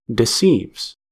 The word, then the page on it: deceives: American English